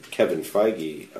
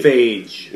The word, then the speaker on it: Feige: The reason we're getting Guardians of the Galaxy is because Kevin Feige, or I'm only assuming that's how you pronounce his name, right? – But I was right on Cillian Murphy. – Feige. – Kevin Feige. – Feige. F-I-E-G-E?